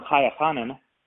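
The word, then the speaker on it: Chaya Chanin: So when Chaya Hanan, right, that's how she pronounces her name, C-H-A-Y-A, space C-H-A-N-I-N,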